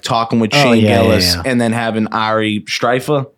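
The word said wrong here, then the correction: Shaffir: Shafir. – I can't do this. – He's probably not going to come on the show because I can't pronounce his name.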